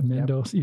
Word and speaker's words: Mendoza: Say that right, Mendoza, yep.